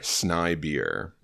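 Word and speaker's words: Snejbjerg: Regarding Peter Snibier, the first part of his last name is pronounced as Sni, S-N-A-I, and the sound is like the ending sound of high. The last part, Bjerg, meaning mountain, is normally pronounced like this, when it is the ending of a name. Thus, the ending G is silent, and the J is more of an I sound than a typical American J. So a phonetic spelling would be S-N-A-I-B-I-E-R, Snibier.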